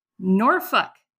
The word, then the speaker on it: Norfolk: I'm going to go with N-O-R-F-O-L-K. – Norfolk. Norfolk. That's how people say it.